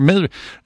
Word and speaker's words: Mrs.: It's Mrs. Greg the Dive Master. And he kind of looked at me confused. He goes, no, you say it like Mizres. And I was like, what is, you know? And so he just explained that when he grew up in the mountains of Virginia, that's how they said MRS is Mizres.